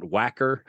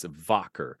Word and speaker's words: Wacker: Well, W's are pronounced like V's in German. So it's not Wacker, it's Wacker. So pronounce that like a V and you'll be pronouncing it correctly.